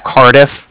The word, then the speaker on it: Cardiff: Calling from the Bank of Cardiff, and that's spelled C-A-R-D-I-F-F, as in Frank Frank. So it's like car and then different.